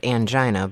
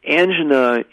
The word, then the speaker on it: angina: Yeah, and what about I always called it angina, but sure. And I think it's described both ways, or it's pronounced both ways. The angina, if you've been given the diagnosis of angina, then that implies that the person using that phrase thinks it is heart disease.